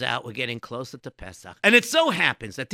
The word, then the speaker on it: Pesach: Because we're getting closer to Pesach, as some people say Pesach, which is the correct pronunciation, but everybody got so used to the Yiddish way of saying Pesach or Pesach or Pesach.